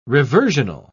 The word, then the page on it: reversional: British English